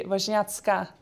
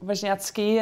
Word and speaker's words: Wozniacki: But if you do it in proper Polish, actually the I in the end would be an A and it would be Wozniacka. So that would be the proper pronunciation, because women in Poland always or usually end on an A, like Radwanska or Damochowska.